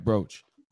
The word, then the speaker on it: brooch: and the WBC brooch. I'm probably saying that wrong. – Yeah, it's right, brooch.